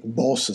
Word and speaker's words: Bosch: Mi hanno accalappiato qui eh, col fatto che posso vedere dei quadri di, di Bosch, che Boss sarebbe la pronuncia giusta,